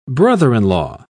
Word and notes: brother-in-law: British English

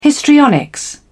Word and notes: histrionics: British English

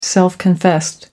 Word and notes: self confessed: American English

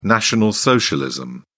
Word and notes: National Socialism: British English

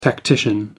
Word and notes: tactician: American English

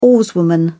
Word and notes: oarswoman: British English

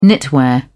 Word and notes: knitwear: British English

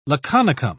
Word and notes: laconicum: British English

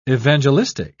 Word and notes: evangelistic: British English

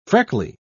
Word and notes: freckly: British English

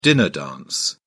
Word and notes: dinner dance: British English

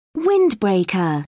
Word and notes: windbreaker: British English

